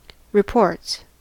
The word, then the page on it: reports: American English